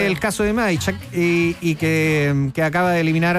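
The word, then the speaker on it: Majchrzak: ¿Cómo se dice? – Kamil Majshak. – Yo dije cualquier cosa, yo lo intenté.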